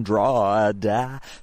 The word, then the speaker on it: Drogheda: It's a craft beer based in Drogheda, spelled D-R-A-W-D-A, if you want to pronounce it correctly, Drogheda, but actually spelled D-R-O-G-H-E-D-A.